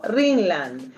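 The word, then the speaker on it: Rinland: Saludo en su día al ingeniero Sergio Ringland, un gran proyectista de Fórmula 1. ¿Lo dije bien? – Muy bien.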